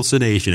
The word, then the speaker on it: sanation: But radical sanation is S-A-N-A-T-I-O-N, radical sanation.